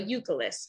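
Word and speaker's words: Youkilis: So Yokilis is how you say it.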